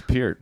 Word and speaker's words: Peart: It's Peart.